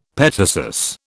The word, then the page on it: petasus: British English